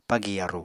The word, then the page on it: pagi yaru: Apatani